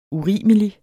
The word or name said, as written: urimelig